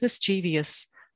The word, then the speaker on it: mischievous: if someone says mischievous, I want to shake them and explain it doesn't rhyme with devious.